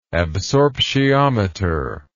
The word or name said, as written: absorptiometer